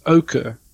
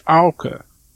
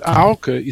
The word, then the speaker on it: Auke: So my name, for example, in South Africa, you pronounce it Auke, almost like the color. But in Frisian, it's Auke. And Auke is an English sound, like cow.